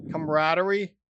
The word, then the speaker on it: camaraderie: Camaraderie. – That's the right way to say it.